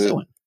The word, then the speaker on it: Methuen: Methuen.